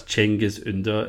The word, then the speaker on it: Cengiz Under: So that's Cengiz Under and, and Pau Lopez.